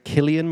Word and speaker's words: Cillian: Cillian Murphy.